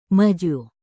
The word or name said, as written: maju